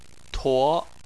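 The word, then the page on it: tuo: tone 2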